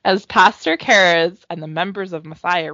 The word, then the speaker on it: Cares: So, the man who started it, his name is Pastor Mark Cares it's spelled like Cares, but it's pronounced Cares.